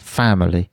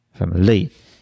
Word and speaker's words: family: So even let's use the word family. So if I'd say it like this, family, the last two syllables I'm almost not saying, but you still recognize the word because I've got the stress right, family. If I say family, it's much more difficult to understand.